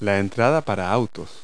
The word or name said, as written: driveway